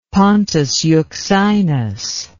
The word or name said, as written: pontus euxinus